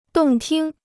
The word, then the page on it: 动听: dòng tīng